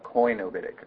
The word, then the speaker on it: koinobitic: The second one is koinobitic monasticism, that funky word, sometimes spelled without the first O, looking like cenobitic or koinobitic, but koinobitic.